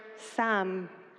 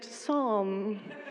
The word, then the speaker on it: Psalm: as we started talking about this series with other people, everyone has been confused by my apparent pronunciation of the word Sam, which is apparently meant to be pronounced Psalm.